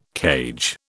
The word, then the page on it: cage: British English